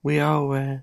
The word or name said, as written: uiaoe